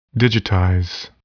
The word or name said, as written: digitize